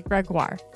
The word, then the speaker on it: Gregoire: Plus, today's guest, Sheila Ray Gregoire, pronounced like Jaguar.